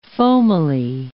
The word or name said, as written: foamily